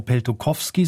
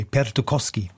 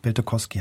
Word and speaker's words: Peltokoski: Ich habe es vorhin falsch gesagt, er heißt gar nicht Tarmo Peltokoski, sondern ohne W, Peltokoski. Peltokoski heißt er.